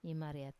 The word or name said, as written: imariat